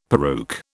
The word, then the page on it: peruke: British English